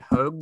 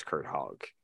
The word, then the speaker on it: Hogg: As Kurt Hogg noted, Ty was pronouncing it Hogue the other day. Do we have an answer? – No, that's just Ty. – Okay. – I feel very confident. I'm open to correction on that. Ty, if you're listening, I'm pretty sure it's Kurt Hogg.